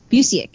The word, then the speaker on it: Busiek: I'm doing Superman Secret Identity by Kurt, I hope I'm pronouncing this right, Busiek? – Busiek.